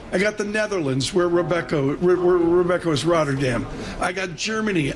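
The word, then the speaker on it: Robeco: I'm going to go broad here, Colin, because it's Rebecca or Rubico. How do you pronounce it, Colin? – Depends if you're Dutch. Yeah. It's Rebecca.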